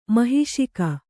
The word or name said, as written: ಮಹಿಷಿಕ